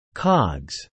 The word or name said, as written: cogs